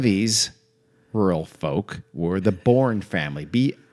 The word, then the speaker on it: Boorn: I've seen it spelled mostly B-O-O-R-N. Sometimes it's spelled B-O-U-R-N, although that might be a modernization of the spelling there. So let's go with, doesn't matter, I'm going to pronounce it the same way.